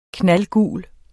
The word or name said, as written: knaldgul